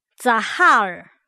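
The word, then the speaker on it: Zahar (זכר): 今天我们要看的字是男性，男性，读音读作 Zahar， Zahar。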